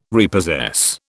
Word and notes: repossess: British English

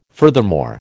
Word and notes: furthermore: American English

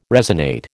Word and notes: resonate: American English

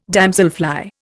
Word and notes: damselfly: Indian

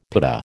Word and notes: pitta: American English

